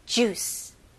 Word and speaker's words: juice: 영어로는 이음절이 아니고 이름절입니다. 그래서 juice. juice. 그리고 마지막에 모음 소리가 나면 안 돼요. juice라고 하면 안 되고, 마지막 소리가 이 c 발음, s, c 이렇게 섞인 발음이죠. juice. juice. 오케이? juice가 아니고요.